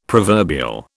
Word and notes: proverbial: British English

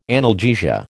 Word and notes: analgesia: American English

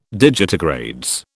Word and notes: digitigrades: British English

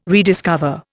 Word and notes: rediscover: Indian

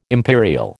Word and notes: imperial: American English

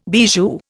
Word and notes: bijou: Indian

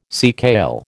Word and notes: seakale: American English

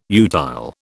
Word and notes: utile: British English

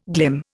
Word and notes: glim: Indian

